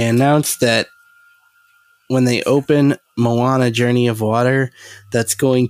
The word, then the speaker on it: Moana: you said Mona. Is that not the pronunciation? That's Moana. Moana? Oh. – Or did I spell it wrong? I don't know. No, it's, it's Moana.